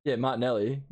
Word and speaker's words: Martinelli: And Matter to how do you say his name? Martinelli getting two goals.